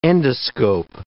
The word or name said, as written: endoscope